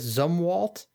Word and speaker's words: Zumwalt: It seems like last Saturday, the USS I hope I'm saying this right, the USS Zumwalt.